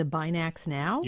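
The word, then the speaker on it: BinaxNOW: I think it's called, if I'm pronouncing this correctly, the Binax Now.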